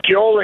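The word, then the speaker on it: ceol: So it's C-E-O-L ampersand crack, C-R-A-I-C, which is how you say it in Irish. Uh-huh. And then Trasna, T-R-A-S-N-A, Na, N-A, and then D-T-O-N-N-A.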